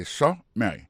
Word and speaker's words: summary: Par exemple, sous premier mot et summary, pour question de prononciation, accent tombé sous premier syllabe là, summary. Ça, c'est summary.